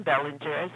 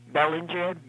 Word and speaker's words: Bellinger: Okay, it's Susan Bellinger, Susan, S-U-S-A-N. Bellinger, B-E-L-L-I-N-G-E-R.